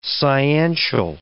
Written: sciential

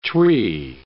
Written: Twi

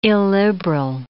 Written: illiberal

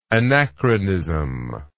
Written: anachronically

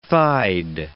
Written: thighed

